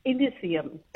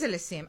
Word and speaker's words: Elysium: Elysium. Yes, no. How am I pronouncing this? – Elysium. – It is Elysium.